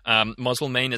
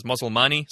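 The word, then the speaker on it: Moselmane: Moselmain is how I've heard most journalists say it, but I know someone with that last name who says Moselmani.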